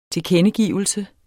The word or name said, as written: TILKENDEGIVELSER